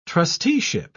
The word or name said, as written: trusteeship